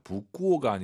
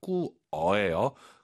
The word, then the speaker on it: 不过: 부구어가 아니라 꾸어예요.